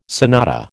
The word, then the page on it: sonata: American English